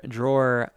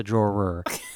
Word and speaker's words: drawer: And then in the drawer, I just have to make sure I pronounce the ER because otherwise when I'm not paying attention to my accent, it's a draw. But it's a drawer.